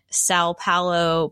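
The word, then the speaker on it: São Paulo: location on here, Stockholm, and Sao Paulo, Brazil.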